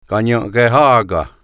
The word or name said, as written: Kanienkehaka